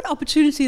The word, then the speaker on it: opportunity: opportunity is a five-syllable word with secondary stresses on the first syllable and the primary stresses on the third syllable.